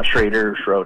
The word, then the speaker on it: Schroeder: Well, that's you might want to spell it for the audience because it doesn't – you don't spell it like it sounds. – Yeah, right. S-C-H-R-O-E-D-E-R. Schrader.